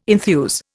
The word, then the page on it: enthuse: Indian